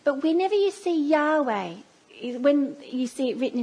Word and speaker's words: Yahweh: But the, the best way we can say it now, the most accurate way we believe it would, be, would have been said from all the thousands of years of scholarly research is that we would say it Yahweh. That's how we would pronounce God's name today.